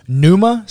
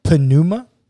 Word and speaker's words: pneuma: The Greek word for spirit is pneuma, spelled with a P, pneuma, but don't say the P.